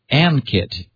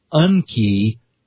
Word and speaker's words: Ankit: Unfortunately, his name looks like you would say Ankit, A-N-K-I-T. I was very nicely informed by a Twitter follower that it's Unki Anubhav. So thank you for providing the pronunciation help.